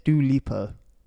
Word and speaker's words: Dua Lipa: Yeah, it's Dua Lipa.